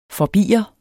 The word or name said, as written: forbier